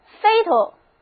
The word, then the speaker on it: fatal: 下面我们把 fate 后面的 e 去掉，加上 al 形容词后缀，这个新的形容词 fatal，第二个 a 不发音，读作 fatal，跟我念一遍 fatal。